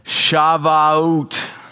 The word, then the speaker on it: Shavuot: Shavuot.